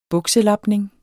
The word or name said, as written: bukselapning